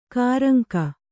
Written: ಕೂರಂಗಿ

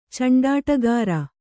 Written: ಚಂಡಾಟಗಾರ

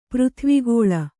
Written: ಪೃಥ್ವಿಗೋಳ

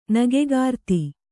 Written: ನಗೆಕಾರ್ತಿ

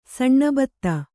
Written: ಸಾನುಭೂತಿ

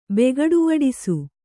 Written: ಬೆಗಡುವಡಿಸು

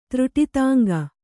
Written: ತ್ರುಟಿತಾಂಗ

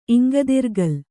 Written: ಇಂಗದಿರ್ಗಲ್

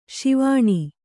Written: ಸ್ವನ

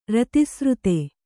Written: ರತಿಸ್ರುತೆ

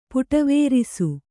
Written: ಪುಟವೇರಿಸು

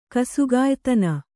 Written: ಕಸುಗಾಯ್ತನ

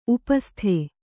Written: ಉಪಸ್ಥ

